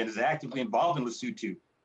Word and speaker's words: Lesotho: Because, and I now understand it's Lesotho. And I said it wrong for years.